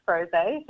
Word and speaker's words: rosé: Well, we've been looking at, I think it's pronounced rosé, so it's a frozen rosé.